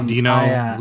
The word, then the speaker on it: Deno: I have not played around with Dino all that much, so I did not know, or Deno. I always forget how it's pronounced. I want to say Dino because it's got the dinosaurs, the logo. – They say Dino.